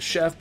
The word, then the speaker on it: Choi: And there was actually, I do not recall the name of the chef, but there was an actual chef, Roy Che or Choi, depending on how you like to pronounce it, because it can go both ways.